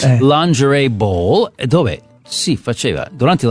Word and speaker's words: lingerie: Un altro spoof è quello della lingerie ball. – Scusa, si dice lingerie. – Lingerie, lin, perché in francese si pronuncia così, lin.